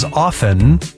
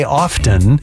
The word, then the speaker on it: often: So we looked it up in the dictionary for the pronunciation, and it turns out that you can pronounce it either way, often or often, same meaning.